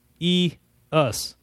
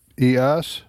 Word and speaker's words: Isaias: Isaias. – Isaias. – Mm-hmm. – Isaias.